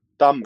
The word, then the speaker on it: Tamm: Tamm. – Tamm. – It's with an accent, so it's like down the middle.